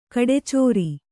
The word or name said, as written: ಕಡೆಚೋರಿ